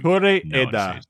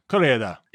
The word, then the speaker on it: Koreeda: I think you Koreeda, but you have to say it fast, like a Japanese person. Koreeda.